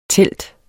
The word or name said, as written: telte